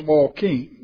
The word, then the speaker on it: Joaquin: It's pronounced Joaquin. Now, if you come from Tenney Hall, that's how you say that.